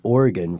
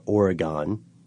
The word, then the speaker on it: Oregon: So in Oregon, for instance, or Oregon, which is apparently the way I'm supposed to be pronouncing this.